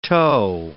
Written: tow

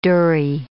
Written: dhurrie

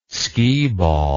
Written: skeeball

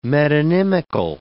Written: metonymical

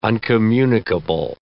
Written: uncommunicable

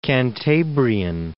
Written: Cantabrian Mountains

